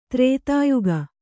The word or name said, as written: ಧೃತಿಯೋಗ